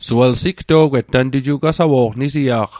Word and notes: sigto'gwet: Mi'gmaq

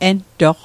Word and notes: entoq: Mi'gmaq

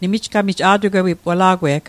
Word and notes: a'tugwewatl: Mi'gmaq

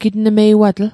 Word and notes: gitnmeiwatl: Mi'gmaq

